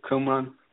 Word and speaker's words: Qumran: Well, they were found in West Jordan, the Qumran, known as Kirbit Qumran.